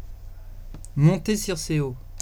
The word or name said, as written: montecirceo